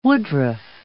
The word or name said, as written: woodruff